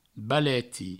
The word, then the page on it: baleti: Provençal